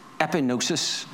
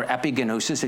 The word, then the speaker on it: epignosis: The word for knowledge here is epignosis, or epigenosis, if you want to pronounce it the Northern Irish way.